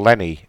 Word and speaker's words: Lenny: I also sometimes use my middle name, which is Leonard or Lenny. – Okay. Can you spell that for me? – Well, Lenny. L-E-N-N-Y.